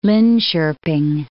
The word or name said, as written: Linkoping